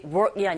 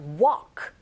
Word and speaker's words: walk: Actually, work이 아니라 walk 이렇게 발음하셔야죠.